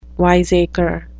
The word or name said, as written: wiseacre